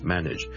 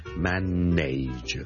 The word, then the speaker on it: manage: 笑叔今日想同大家讲 manage 呢个字嘅发音，manage M A N A G E manage。由于呢个字后面 N A G E 咧，nudge 咧。是串做 NAGE，很多人就会将它读成 manage 的。但是事实上呢，我这个字是读 manage, nudge, nudge, nudge。你当是好像 NIDGE 那样读。